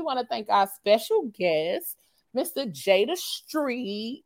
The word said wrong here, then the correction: Screet: The Screet. I'm sorry. I kept putting the T. Mr. Jay the Screet. – Yeah. We spell it like I say it. – Yeah, the Screet. And yeah. I kept saying street, you know. I saw the K, but kept saying T.